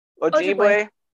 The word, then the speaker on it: Ojibwe: the Ojibwe. – Ojibwe.